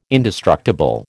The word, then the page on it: indestructible: American English